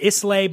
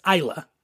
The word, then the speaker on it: Islay: So it's spelled Islay. A lot of people mispronounce it Islay, but it's Islay.